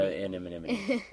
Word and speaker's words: anemone: Did an, I say that right? An anemone. An uh, anemone. That's right.